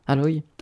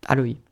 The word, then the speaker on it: Aloy: Je kiffe Aoi, Aoi, ah, oh, Aol, je sais pas comment on prononce. Aloy, Aloy.